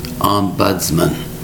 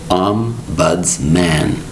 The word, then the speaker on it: ombudsman: Today's word is ombudsman. Ombudsman. It's quite a long word, and it only has three distinct syllables. Ombudsman. Ombudsman. Spelled O-M-B-U-D-S-M-A-N.